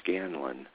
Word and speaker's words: Scanlan: You can go to the Kendall Hunt website, K-E-N-D-A-L-L, Hunt. And in the search box, if you just type in my last name, Scanlan, S-C-A-N-L-A-N, I've been fighting for that spelling all my life.